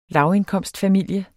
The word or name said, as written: lavindkomstfamilie